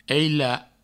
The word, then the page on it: aila: eila